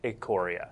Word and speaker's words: Ikoria: So we haven't yet announced the pre-release or release dates for Theros Beyond Death or Ikoria. – Yep. – You may not have heard it pronounced that way before. I was told immediately before the show it is Ikoria.